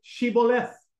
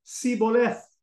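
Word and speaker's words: Shibboleth: If they say Siboleth, it means they are from Ephraim, because the Ephraimites, for some reason, were unable to pronounce the H.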